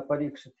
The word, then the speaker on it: Парикшит: Удачливый царь Парикшит, так ударение на Парикшит, я обратил внимание,